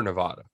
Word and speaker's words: Nevada: Are we Nevada or Nevada? – It's Nevada. – Nevada. Okay. Yeah. We'll say it how the locals say it, but we are officially on the Nevada bandwagon.